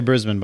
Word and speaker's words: Brisbane: You're supposed to say Brisbane, by the way.